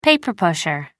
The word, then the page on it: paper-pusher: American English